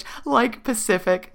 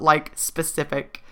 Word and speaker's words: Like Pacific: Like Pacific, not Like Specific.